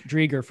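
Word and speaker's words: Driedger: It's going to pay dividends in fantasy, but we're going to go Chris Dreger from now on. – Yeah. Just, listen, we're acknowledging the listeners, you know, help us out with the pronunciation.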